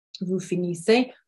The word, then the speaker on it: finissez: And then vous, which is you, plural or and or formal, can be singular and plural, is finissez, so I-S-S-E-Z, so vous finissez. Vous finissez, our E-Z, our Z on the end there is silent as well.